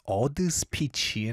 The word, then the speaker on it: ADDSP2CH: His Korean pronunciation is Adspeechy and it's spelled A-D-D-S-P-2-C-H.